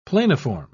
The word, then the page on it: planiform: British English